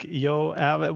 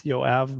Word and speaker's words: Yoav: Yeah, well, it is like Yoav, which it comes from the Bible. So it's I think the English pronunciation is Joab. That's why it's Yoav, but you got it.